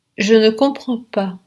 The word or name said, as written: je ne comprends pas